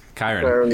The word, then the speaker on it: Kyren: Kieran? I don't even know how to say his name yet. – Kyren Williams. – Kyren.